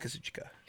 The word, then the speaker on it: Kazuchika: Also, is it Kazuchika or Kevin Kelly, who was the main announcer of New Japan? He says Kazuchika. Kazuchika. – I think Kelly just like overpronounces it. – Okay, so it is Kazuchika. – Yeah, every other person that I've heard, including like Japanese people, says Kazuchika.